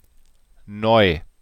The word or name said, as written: neu